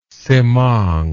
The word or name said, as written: Semang